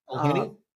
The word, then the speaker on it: McElhenny: McElhenny.